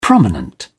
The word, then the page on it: prominent: British English